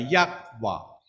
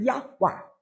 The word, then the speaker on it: 耶华: 如果要发音呢，他的音呢就是耶华，如果你要发音的话，他的发音就是耶华。